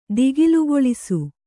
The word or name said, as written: ದಿಗಿಲುಗೊಳಿಸು